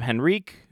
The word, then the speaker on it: Henrique: Adam Henrique, who had a pretty damn good game a couple days ago. – I believe the H is silent.